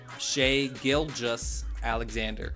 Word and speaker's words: Shai Gilgeous-Alexander: I always butcher that name. Let's say it right. Shai Gilgeous-Alexander.